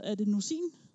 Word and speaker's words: adenosin: Altså, inde i hjernekringlekrogene her, din hjerne, mens du er vågen, den producerer et stof, som hedder adenosin. Det ved jeg ikke, om jeg udtaler rigtigt. Altså, adenosin, ikke også? – Adenosin. – Adenosin, ja.